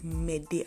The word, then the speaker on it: Mede: So my name is Mede Akran. That is spelled M-E-D-E. Then my surname is Akran, A-K-R-A-N. So hope